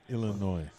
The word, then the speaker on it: Illinois: It's a silent S, bruh. My mom's from Wisconsin. Trust me on this. Illinois.